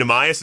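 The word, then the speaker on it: Neemias: How do I pronounce his first name? Nemias, is that right? – You got it. Okay. You nailed it.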